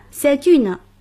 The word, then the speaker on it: c'est une: 注意 c'est un, c'est une 都需要连诵，读成 c'est un, c'est une.